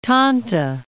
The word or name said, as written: Tanta